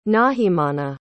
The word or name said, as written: nahimana